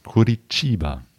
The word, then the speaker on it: Curitiba: Spricht man das aus? Ja, genau, mit so einem leichten Curitiba.